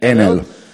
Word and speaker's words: Enel: Enel is a I think in Italy, in Italian, we pronunciate it Enel.